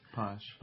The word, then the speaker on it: Pax: You've been saying Pax like the Latin, and you pronounce it Posh. – Posh. As it's a Spanish kind of a sound.